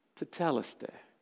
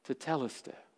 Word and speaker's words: tetelestai: And he says, "Tetelestai." Tetelestai. You probably heard it pronounced tetelestai. It's tetelestai.